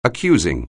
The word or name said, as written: accusing